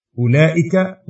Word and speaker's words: أولئك: فهي زائدة لا تنطق، إذ الأصل أولئك أولئي، ويشترط، يعني يقصد أنها من غير واو، فكتب أولئك وأولئي بدون واو.